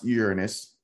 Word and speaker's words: Uranus: Then you have Jupiter, Saturn, Uranus, it's pronounced Uranus, Neptune, and the infamous Pluto debacle, because Pluto was a planet and then it got reclassified.